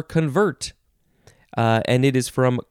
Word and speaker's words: convert: Second form, this one is pronounced convert, with the first syllable being emphasized.